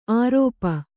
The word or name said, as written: ಆರೋಪ